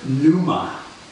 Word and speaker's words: pneuma: The word in the New Testament in the Greek is the word pneuma. Pneuma. I don't know how exactly to pronounce that. There's a silent P. – It's like pneumonia.